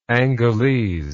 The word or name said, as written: Angolese